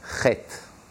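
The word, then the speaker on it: Chet (חטא): The Baal Shem Tov said, the word sin is spelled in Hebrew, ches, tes, aleph. Chet. The aleph is silent. You only hear the ches and the tes, chet.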